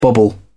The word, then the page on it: bobble: British English